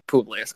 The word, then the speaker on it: Publius: How do you pronounce it? – We say Publius. You said it. – Okay, Publius, cool.